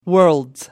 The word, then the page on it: worlds: British English